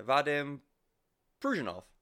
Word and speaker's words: Vadim Pruzhanov: First album without, I'm going to try and pronounce his name, aren't I? Vadim Pruzhanov. I think I did it right for that.